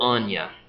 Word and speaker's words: Ania: Now, do you say Anya or Anya? – Yeah. – One, two syllables or three? – It's funny. I get that question a lot. I always say Anya. – Anya, two syllables.